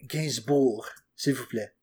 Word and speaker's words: Gainsbourg: Yeah, and also, I apologize, like, to all the French people for pronouncing his name however I just pronounced it. – Gainsbourg, s'il vous plaît.